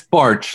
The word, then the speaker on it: Barch: Yeah, it's Barch, like the month of March.